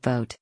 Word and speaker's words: Vought: Vought, pronounced vote,